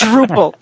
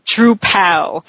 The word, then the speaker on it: Drupal: It's Drupal. It's not Drupal. Remember, it's a CMS. It's not a drag queen.